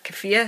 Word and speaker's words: keffiyeh: I bought a keffiyeh, is that how you say it? Mm-hmm.